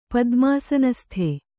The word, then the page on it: ಪದ್ಮಾಸನಸ್ಥೆ: padmāsanasthe